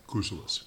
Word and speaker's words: Kousoulas: Kousoulas.